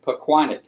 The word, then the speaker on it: Pequannock: Pequannock is how you say it. It's very hard.